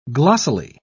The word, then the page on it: glossily: British English